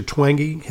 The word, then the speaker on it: Twenge: By the way, one of your colleagues, who I believe was also involved in the JANA open letter, Professor Twenge has written, am I pronouncing the name correctly? – That's correct, yep.